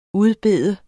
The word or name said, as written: udbede